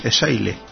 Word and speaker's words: Xaile: XA y Latina LE. – Xaile. – Sí, Xaile.